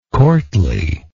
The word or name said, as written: courtliness